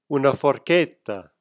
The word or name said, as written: forchetta